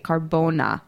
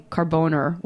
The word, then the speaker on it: Carbona: C-A-R-B-O-N-A, Carbona. That's not me pronouncing Carboner with a Boston accent, I promise.